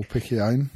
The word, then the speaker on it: Piccione: On came Piccioni. – Or Piccione. – Piccione, even.